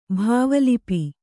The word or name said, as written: ಭಾವಲಿಪಿ